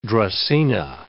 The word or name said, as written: dracaena